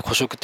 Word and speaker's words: 孤食: で、あとはお一人様ってワードも流行ったんですけども、これも流行ったことによってこの孤食市場、まあ孤独に食べるって書いて孤食って読むんですけど、孤食市場が広がっていったんですよね。